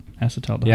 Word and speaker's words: acetaldehyde: So it probably hits all the categories you want, and maybe dock it just a bit for the acetaldehyde. Crap, I said it wrong. No, I didn't. – Yeah, you said it right. Acetaldehyde.